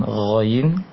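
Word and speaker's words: Ghain (غين): Pengucapan Ghain, tempat keluarnya, tempat mulainya huruf ini sama dengan Kho, hanya saja bedanya cara pengucapannya. Kalau Kho, cara mengeluarkannya dialirkan angin ketika mengucapkannya, sedangkan Ghain ditahan angin tersebut di tenggorokan atas, ya, tenggorokan atas.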